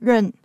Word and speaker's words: 润: 润国语发音是 run，是英文 run 的谐音，近年来在大陆被广泛用作移民的代名词，来规避严格的网络审查。